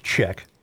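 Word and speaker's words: Czech: Mike Check writes, it's C-Z-E-C-H. I'm going to pronounce it Check. – Check, yes.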